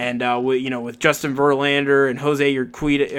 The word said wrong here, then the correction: Urquidy: or uh, Urquidy, I believe I, I, said it correctly. I'm terrible. – Urquidy, yeah.